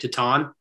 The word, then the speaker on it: Titane: It's Titane. Is that right? Titane. Spelled T-I-T-A-N-E.